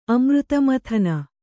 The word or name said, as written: ಅಮೃತಮಥನ